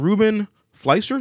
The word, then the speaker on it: Ruben Fleischer: Is joining Ruben Fleischer.